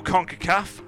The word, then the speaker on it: CONCACAF: Should we go CONCACAF? Have I said that correctly? – You have said that correctly,